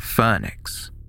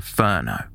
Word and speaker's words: Furneux: Although the caller misspelt the name Hollyhock, using H-O-L-L-Y-C-O-C-K, he knew to correctly pronounce the name of the village, saying Phoenix. Outsiders often referred to the area using the French pronunciation of Furno. When the caller was asked how to spell Phoenix, he spelt it F-U-R-N-E-A-U-X.